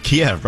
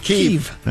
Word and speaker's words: Kyiv: And that reminds me of the whole debate we had on how to pronounce Kiev. Most of us said Kiev, right? Although now it's Kyiv. – It's Kyiv.